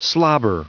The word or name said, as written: slobber